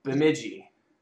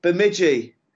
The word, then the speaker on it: Bemidji: You're out in uh, Benjami. Did I say that correct? – Uh, Minnesota? – No, not quite. It's Bemidji. Bemidji.